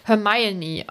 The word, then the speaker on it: Hermione: Und im Englischen wird halt genau erklärt, dass es Hermione ausgesprochen wird, weil das war wohl irgendwie ein Ding.